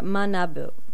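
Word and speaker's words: manabu (学ぶ): It's pronounced gaku or manabu.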